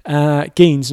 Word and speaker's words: Geens: And you have this guy, Yelly Greens. I think that's Jelly. I'm pretty sure that's how you say it, from Belgium. – Spelled like Jelly. – Geens, not Greens.